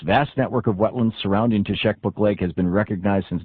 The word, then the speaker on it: Teshekpuk: Teshekpuk Lake in northern Alaska, it's pronounced T-E-S-H-E-K-P-U-K.